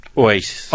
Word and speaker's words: Oasis: Oye, y nada más déjame comentarle, estaba escuchando, ya ves cómo le han hecho el cuento que Oasis, ¿o cómo se dice? – Oasis.